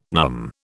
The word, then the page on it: NUM: British English